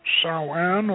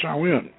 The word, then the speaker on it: Samhain: whose original spelling was S-A-M-U-I-N, pronounced Samhain or Samhain.